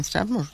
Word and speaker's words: Strathmore: En Strathmore, sí, así se pronuncia, la verdad.